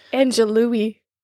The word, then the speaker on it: Angelou: I don't know if I'm saying that right. I really need somebody to correct me before I cry. Am I saying that right? There's like six people Angelou. – In the room. Is it Angelou or Angelou? – Angelou. – Isn't it L-O-U at the end? – Angelou.